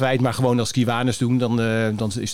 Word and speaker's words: Kiwanis: Is het nou Kiwanis, Kiwanis? Kiwanis. Kiwanis. En het is, ja, je kan het uitspreken. En uh, waarschijnlijk een Amerikaan spreekt het anders dan wij uit.